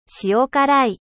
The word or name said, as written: 塩辛い